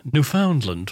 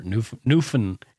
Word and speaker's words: Newfoundland: But it's Newfoundland. – It's Newfoundland. – Yeah. Well. If you're from the East Coast, you just call it Newfoundland or Newfin. You don't even pronounce the D.